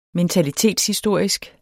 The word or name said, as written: mentalitetshistorisk